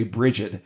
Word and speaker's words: Brigid: And then there was Briad, or if you want to pronounce it the more American way, Brigid,